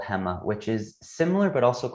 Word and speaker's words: Hema: So if I could ask you again to maybe just give a little bit of your experience about your time in Alibaba's, I think I'm pronouncing it right, Hema, right? – Yep. Yeah, that's exactly right.